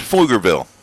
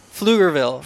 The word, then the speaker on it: Pflugerville: That's Pflugerville. – Pflugerville. – Pflugerville, Texas.